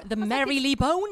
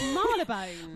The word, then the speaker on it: Marylebone: The Merrily Bone? – It's Marylebone. – Marylebone.